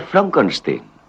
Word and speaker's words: Frankenstein: Doctor Frankenstein? Frankenstein. ¿Me toma el pelo? No. Se pronuncia Frankenstein.